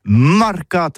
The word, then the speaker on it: marcat: Eh bien, d'ici à tous, mais le mercat de l'Uni ou le mercat des rebelles, mais en gascogne toulousain, c'est prononcé sur les marcats, avec un A marcat.